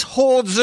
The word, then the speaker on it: horde: Pronunciation? – Horde, same thing. – Same.